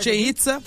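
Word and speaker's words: Chichén Itzá: Chichen Itza, como é que fala? – Chichen Itza.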